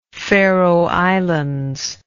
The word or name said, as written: Faroe Islands